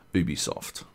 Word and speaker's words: Ubisoft: As we learned this week, it is definitively Ubisoft.